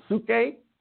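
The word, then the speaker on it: psuche (ψυχή): and then there's psyche or psuche, as it's pronounced in Greek, but it looks just like our word psyche, the soul, the mind, will, and emotions, and then the soma, the body.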